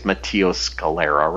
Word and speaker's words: Matteo Scalera: I think it's Matteo Scalera, right? – Mm-hmm. – Isn't that how you pronounce his name? – Yep.